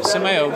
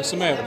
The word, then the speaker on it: Samael: Samael, the light bearer, though he eventually became better known as Lucifer. So Samuel, who's Samuel? – Samael. – I keep saying it wrong, Samael.